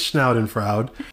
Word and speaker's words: Schadenfreude: Is that how you say that? It's a German thing or whatever, but it's the most. – Oh, we have German listeners. Yeah, Let us know. – I believe it's Schadenfreude, which basically means you take joy in someone else's misery, right?